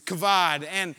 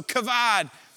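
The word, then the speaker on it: kavod (כָּבוֹד): And so I took my Hebrew lexicons off the shelf and I opened up and I'm with Hunter and we're arguing over how to pronounce this word. And we landed on the way I wanted to pronounce it, which was kavod.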